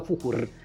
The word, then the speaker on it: Fuchur: Ja pamiętam, że gdzieś w każdym tak nazywa Fuchuo, on się nazywa Fuchur, ale Niemcy tak wymawiają końcówki. Więc gdzieś na pewno istniała jakaś polska wersja, która nazywa się Fuchuo z takim bardzo wyraźnym o na końcu.